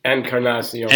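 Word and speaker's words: Encarnación: Encarnacion.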